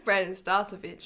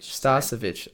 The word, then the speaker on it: Starcevich: Brandon Starcevich. – Starcevich.